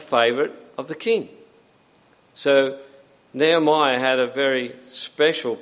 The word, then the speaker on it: Nehemiah: But the name Nehemiah, pronounce it right, Nehemiah means comfort or consolation of Jehovah.